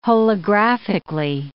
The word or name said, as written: holographically